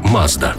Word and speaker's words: Mazda: Назва також походить від імені засновника компанії Мацуда, яке японською вимовляється як Mazda.